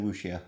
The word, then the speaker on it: Wuxia: Wuxia, is that how you pronounce it? – I would say Wuxia.